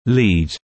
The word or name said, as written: lead